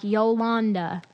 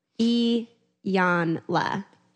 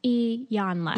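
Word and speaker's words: Iyanla: I know I was, because my I say, like, I call her Yolanda, and that is not her name. There's not even, it doesn't begin with the Y. – Yeah. – And I'm like, Yolanda. – Rob, who was on the biracial episode, loves her, and he he responded in my Instagram story and said, be better, pronunciation, E-Yan-la.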